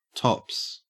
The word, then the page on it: tops: Australian English